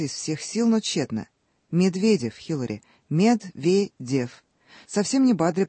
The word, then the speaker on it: Медведев: Медведева. Что-то в этом роде. Да. – Итак, то ли Медед, то ли Медедевар.